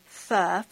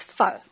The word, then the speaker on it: pho: If you're Vietnamese, which is the cuisine that made pho really popular in the United States and especially in Minnesota, it's pronounced a softer pho, phonetically F-U-H. And if you're Hmong, it's pho, which is a lot more stern and a little more staccato stop, so it's pho. Notice the two?